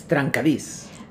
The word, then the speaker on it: trencadís: Then I read about trencadís. Am I pronouncing that properly? – Yes, trencadís.